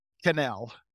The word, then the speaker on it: Quesnel: Quesnel. – And that's Quesnel. Pronounced? – Quesnel. It's French Canadian.